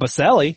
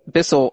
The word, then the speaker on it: Bissell: It's not Baselli. It looks like Bissell. – No, I have a friend. Bissell is how.